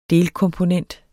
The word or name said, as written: delkomponent